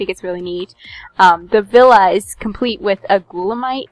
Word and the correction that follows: Gulanite: fine, mine excuse me actually i think it's pronounced gulanite and that's from the mines in defiance so it's kind of a nice little crossover cool.